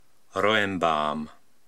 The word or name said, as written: Roembaam